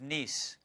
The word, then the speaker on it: Nice: And we were in the city of Nice, spelled like nice, but it's Nice.